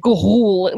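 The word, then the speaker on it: Ga'Hoole: Talk about a language that nobody understood until the VO of the trailer guy kept going, oh, really? – Gahool. Like, Gahool. There's no way you could pronounce that just reading it off of the poster.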